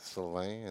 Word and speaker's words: Sylvain: I think the English people had a lot of a problem with Sylvain and pronounce it.